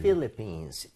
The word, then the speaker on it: Philippines: 그리고 필이 아니라 Phil. – Philippines. 이렇게 가달라는 거.